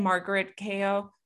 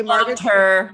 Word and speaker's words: Margaret Cho: and Margaret Cho. – Margaret Cho. – Sorry, I said that wrong. Yep. I butchered that. I'm sorry. So she's Erin. So sorry about that, guys.